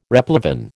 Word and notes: replevin: American English

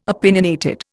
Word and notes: opinionated: Indian

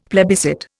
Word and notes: plebiscite: Indian

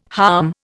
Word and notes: harm: Indian

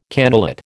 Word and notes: cannulate: American English

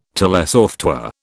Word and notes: telesoftware: British English

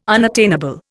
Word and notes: unattainable: Indian

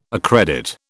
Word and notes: accredit: British English

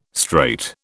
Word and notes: strait: British English

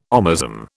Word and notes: omasum: British English

